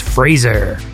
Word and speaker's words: Fraser: And then Fraser Davidson, who corrected me because I called him Fraser Davidson, and he's let me know that I'm to use the like British pronunciation, which is Fraser. But you know how I feel about that. If you want it to be Fraser, spell it with a Z.